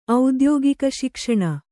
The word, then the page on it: ಔದ್ಯೋಗಿಕಶಿಕ್ಷಣ: audyōgika śikṣaṇa